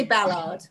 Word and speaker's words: Ballard: I say Ballard. Ballard. So I put the emphasis on the first syllable.